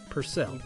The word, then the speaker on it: Purcell: But he says to pronounce his name, his last name is pronounced Purr, like the cat, then add the word cell. So it's Purcell or Purcell.